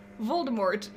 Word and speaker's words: Voldemort: Voldemort. You, there's a T at the end.